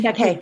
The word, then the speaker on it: Baquet: But I wonder when somebody like Dean Paquet, Beck, I, I think that's how you pronounce his last name. – Dean Paquet, yep.